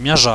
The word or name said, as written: miaza